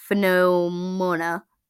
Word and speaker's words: pneumonia: And I know pneumonia may look like pneumonia, but it's the P is silent.